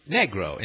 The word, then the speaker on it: negro: Well, it's always just translated to the word negro, pronounced negro in Spanish, meaning simply the color black, or a black person.